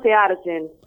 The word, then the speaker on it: Seargen: Porque no pudimos encontrar cómo se pronunciaba. ¿Sirgen será? ¿O Searjen? – No sé, nosotros le decimos Searjen.